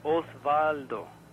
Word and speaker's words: Osvaldo: It's a long O sound. Osvaldo.